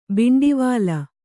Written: ಭಿಂಡಿವಾಲ

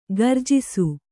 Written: ಗರ್ಜಿಸು

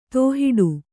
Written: ತೋಹಿಡು

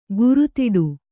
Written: ಕೋರೆದಾಡೆ

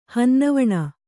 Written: ಹನ್ನವಣ

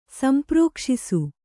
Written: ಸಂಪ್ರೋಕ್ಷಿಸು

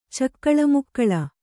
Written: ಚಕ್ಕಳಮಕ್ಕಳ

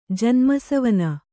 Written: ಜನ್ಮಸವನ

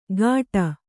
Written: ಗಾಟ

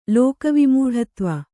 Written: ಲೋಕವಿಮೂಢತ್ವ

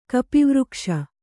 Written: ಕಪಿವೃಕ್ಷ

